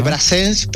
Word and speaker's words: Brassens: Se escribe Brassens, pero se pronunciaría Brassens.